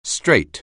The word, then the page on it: straight: anglais